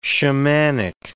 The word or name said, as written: shamanic